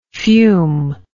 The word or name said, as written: fumingly